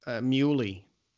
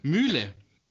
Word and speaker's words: Mühle: And what about Muley? – Mühle. – Mühle. – Mühle. Okay. Mühle Glashütte.